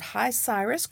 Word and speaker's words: HiCyrus: Our guest, he's a writer, speaker, and consultant on a wide range of current affairs, he is co founder of HiCyrus. Correct me if I'm not saying that correctly. Data- Absolutely correct.